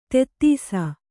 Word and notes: ತೆತ್ತೀಸ: tettīsa

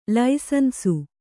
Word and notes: ಲೈಸನ್ಸು: laisansu

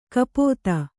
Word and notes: ಕಪಿತೆ: kapite

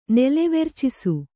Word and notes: ನೆಲೆವೆರ್ಚಿಸು: Kannada

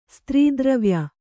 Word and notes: ಸ್ತ್ರೀದ್ರವ್ಯ: Kannada

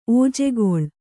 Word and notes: ಓಜೆಗೊಳ್: ōjegoḷ

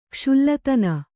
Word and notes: ಕುಶಲತನ: kuśalatana

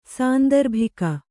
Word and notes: ಸಾಂದರ್ಭಿಕ: sāndarbhika

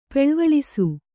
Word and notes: ಪೆಳ್ವಳಿಸು: peḷvaḷisu

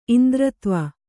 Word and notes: ಇಂದ್ರತ್ವ: Kannada

